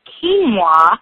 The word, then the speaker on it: quinoa: It's quinoa, and that is spelled Q-U-I-N-O-A, quinoa.